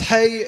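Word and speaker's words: hey (ה): Number two, the word H, or the letter H, it's actually pronounced hey, actually, in Hebrew.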